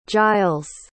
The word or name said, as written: gilles